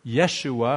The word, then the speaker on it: Yeshua: And of course, that's symbolic and important because Joshua, the Hebrew word Yeshua, if we were to pronounce it probably a little more accurately, means Yahweh saves, or Yahweh is Savior.